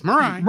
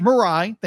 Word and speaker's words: Mirai: And then we see you have this upbeat chipper Mirai, right? – Yes, Mirai. – Mirai, thank you.